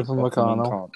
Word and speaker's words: Upamecano: Upamecano.